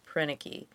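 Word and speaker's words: Proenneke: Prenicke.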